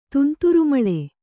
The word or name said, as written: ತುಂತುರುಮಳೆ